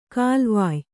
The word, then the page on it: ಕಾಲ್ವಾಯ್: kālvāy